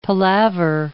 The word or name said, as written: palaver